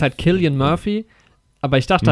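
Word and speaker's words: Cillian: halt, dieses C wird immer mit Z ausgesprochen, aber ich habe dann irgendwann mal ein Christopher Nolan-Interview gesehen, wo er halt Killian sagt und anscheinend ist das halt, wird es halt Killian ausgesprochen.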